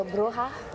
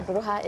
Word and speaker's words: Bruja: Okay, so Bruja. – Bruja, okay. – Yeah, Bruja is witch in Spanish.